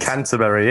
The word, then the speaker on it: Canterbury: And other than that, you spend your time in a small English city called Canterbury. Right? – Canterbury, yes. – Did I say that right? – Very correct.